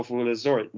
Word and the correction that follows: Zalatoris: Will Az- can't even say his name now. Will Zalatoris.